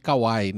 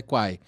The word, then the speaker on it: Kwai: E aí, no, no Kwai, é, é Kwai, né, que a gente fala? É, o pessoal chama muito de Kawaii, né? Mas é Kwai. – É, pela pronúncia dos chineses lá, que eu participei da reunião, eu, eu repito o que eles falaram, é Kwai, eles é falam Kwai.